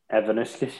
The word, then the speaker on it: Ivanauskas: Ivanauskas, yeah.